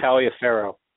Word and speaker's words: Taliaferro: Lorenzo Taliaferro.